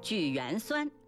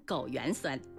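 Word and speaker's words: 枸橼酸: 举缘酸差点被我说成枸原酸。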